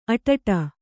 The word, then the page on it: ಅಟಟ: aṭaṭa